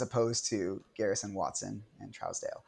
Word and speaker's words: Trousdale: I mean, like, is that how you pronounce his name? – Yeah, Jerry Trousdale, yeah. – Trousdale.